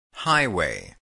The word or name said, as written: highway